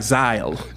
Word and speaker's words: Zile: Z-I-L-E. – Z-I-L-E. Looks like Zile. – Zile. – Okay. – Yeah, you can pronounce it Zile.